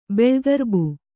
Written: ಬೆಳ್ಗರ್ಬು

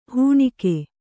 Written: ಹೆಣಿಗೆ

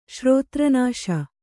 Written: ಶ್ರೋತ್ರನಾಶ

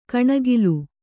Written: ಕಣೆಕಾಲ್

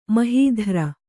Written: ಮಹೀಧ್ರ

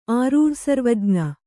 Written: ಆರೂಢಸರ್ವಜ್ಞ

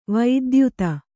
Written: ವಿದ್ಯೋತ

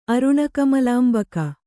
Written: ಅರುಣಕಮಲಾಂಬಕ

